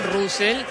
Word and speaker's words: Russell: No, pronunciación la real es Russell. Sí. Muchos le dicen Russell, está perfecto, pero la pronunciación correcta es Russell.